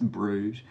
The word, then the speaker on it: Bruges: Born in Brugge, Switzerland, or Bruges, I suppose it's probably pronounced. It's probably pronounced Bruges.